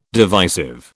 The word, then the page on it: divisive: British English